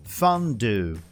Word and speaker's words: fondue: Fundi se escreve da mesma maneira, mas a gente vai falar fondue fondue.